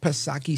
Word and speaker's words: Psaki: Remember Jen P. Circle Back Psaki, silent P, of course.